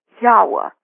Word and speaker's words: Yahweh: And I believe it should be Yahweh. And the same thing with Joshua. J-E-H-O-S-H, Yahshua, U-A, if that sounds reasonable to you.